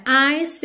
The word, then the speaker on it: ice: Porque ice termina em E, o E não é pronunciado. Então, do C você já vai para o is.